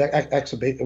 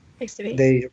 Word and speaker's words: Extubate: They removed the, you know, the tube, exabate, whatever they call it. – Extubate.